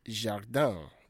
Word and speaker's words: jardin: You guys are having issues with the pronunciation of garden. It is jardin. Le jardin. L-E for low. Jardin. Le jardin, not jardin.